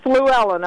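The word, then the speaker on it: Flewellen: My name is Betty, and my last name is Fluellen. I'll spell that for you. Okay. F is in Frank, L-E-W-E-L-L.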